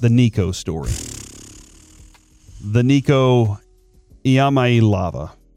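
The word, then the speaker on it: Iamaleava: Iamaelava is how you say, according to him. That's what I watched a video that said that.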